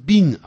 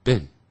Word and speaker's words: been: 现在完成进行时，他的这种句型很简单，用 have, has, have, what is has, 加 been, B-E-E-N, 不要念成 been 啊，要念成 been, it 的音啊 ,it, 短音啊，短元音啊 ,been, 然后再放现在分词。如果你念 being,B-E-I-N-G, 要念 being, 啊，说 the job is being done, 这工作正在被做。 The letter is being written, B-E-I-N-G, 念成 being, B-E-E-N, 念成 Ben。